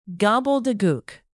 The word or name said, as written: gobbledegook